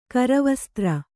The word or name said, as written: ಕರವಸ್ತ್ರ